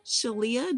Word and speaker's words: Sheleah: Today, I have the honor of interviewing Shalia.